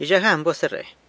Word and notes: Yzhajan buas, erre.: San Lucas Quiaviní

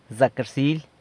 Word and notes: Zacarsily!: San Lucas Quiaviní

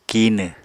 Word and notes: kinw: Apatani

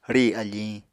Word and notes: rì afiñ: Apatani

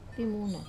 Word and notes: pimuna: wamesa

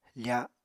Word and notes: fa: Apatani

